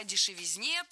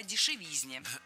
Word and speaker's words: дешевизне: Кстати, о дешевизне. Самый дешевый автомобиль, как известно, по-моему, $400 он стоил. – Кстати, о дешевизне прозвучало не кстати.